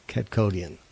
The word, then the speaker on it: Kadkhodaian: Kadkhodaian.